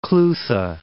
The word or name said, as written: Clutha